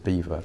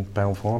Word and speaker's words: Belvoir: I think if you if you're someone from Lincolnshire, then um, and you see the name you would, you would definitely pronounce it beaver. Um, the, the, the sort of beaver reference, in fact, if you wind the clock back a thousand years, the pronunciation would have been Belvoir, beautiful view. Um, it was sort of anglicised many, many centuries ago to beaver.